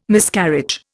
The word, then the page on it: Miscarriage: Indian